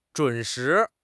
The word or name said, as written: zhun3 shi2